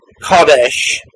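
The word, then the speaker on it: kodesh (קֹדֶשׁ): Burkhoff presents the Hebrew word, which is pronounced more or less kodesh.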